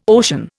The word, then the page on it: ocean: Indian